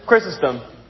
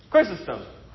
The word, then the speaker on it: Chrysostom: How to pronounce his last name? And spoiler alert, it's not his real last name. – Chrysostom. – Chrysostom? Close? Close enough. Chrysostom. Chrysostom.